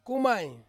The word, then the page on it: kumai: Ansus